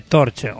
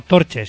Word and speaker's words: Torche: Torch, o Torche, si lo quieres pronunciar tal y como lo leemos.